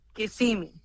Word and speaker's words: Kissimmee: Así se pronuncia, ¿no? ¿Kissimmee o Kissimmee? – Kissimmee. – Kissimmee. Oh, bueno. ¿Quiere conocer Kissimmee? Que se escribe K-I-S-S-I-M-M-E-E.